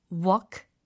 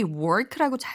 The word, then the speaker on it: walk: Sort of a, sort of a, walk, walk. 많은 분들이 work라고 잘못 발음하세요. 네, walk라고 발음하셔야 되겠죠?